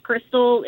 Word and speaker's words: Chrystal: It's Crystal, and it's spelled C H R Y S T A L, just like Christmas and you'll find me.